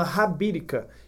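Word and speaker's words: Habitica: E tem esse aplicativo que se chama Rabirica. Ele se escreve como se fosse escrever hábito, tá? Com H no início, a-H-A-B-I-T-I-C-A.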